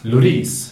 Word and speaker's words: Loris: Loris. Loris. Man säger låris med betoning på sista stavelsen enligt Loris.